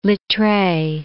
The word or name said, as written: Littre